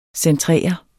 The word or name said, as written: centrere